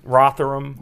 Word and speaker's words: Rotherham: Rotherham. – There's no, you don't make the TH.